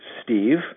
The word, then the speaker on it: Steve: My name is just pronounced Steve, which rhymes with sleeve, and Henry, which rhymes with, penwee, which in Scotland is a very small writing instrument.